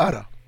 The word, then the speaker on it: Ara: Yeah, Ara.